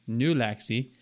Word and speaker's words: Nulaxy: the Nulaxy, Nulaxy, like Gulaxy, but with an N, Nulaxy.